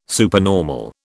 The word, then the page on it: supernormal: British English